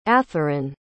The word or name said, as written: atheron